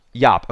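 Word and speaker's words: Joop: So is it Yop or Job? How do you pronounce his name? – Yop. – Okay, so Yop is coming to TPF for a Saturday 3 p.m.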